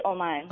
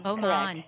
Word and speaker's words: Oman: Is it Oman or – It is Oman. – Oman. – Yeah.